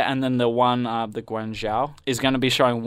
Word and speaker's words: Guangzhou: Shanghai, I'm going to struggle to pronounce this one, Guangzhou. – I'm pretty sure it's Guangzhou.